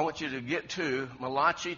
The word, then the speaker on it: Malachi: It's Malachi.